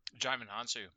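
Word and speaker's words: Djimon Hounsou: Jimon Hounsou.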